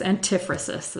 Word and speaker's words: antiphrasis: I want to say antiphrasis or antiphrasis or something, but it's antiphrasis is the correct pronunciation.